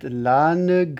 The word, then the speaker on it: l'âne: So this is, I mentioned that certain syllables, you'd normally say l'âne, but when it's sung to two notes, it's l'âne, l'âne gris. But you don't put too much emphasis on the ne. It's, you kind of pass through it.